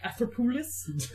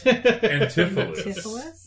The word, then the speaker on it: Antipholus: but the other one is like Atherpoulos. – Antipholus.